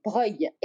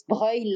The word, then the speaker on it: Breuil: Well, you could read it like in Italy, B-R-O-G-L-I-E, Breuil. It's Breuil like the Italian Breuil, Brogli, Breuil, B-R-E-U-I-L, Breuil.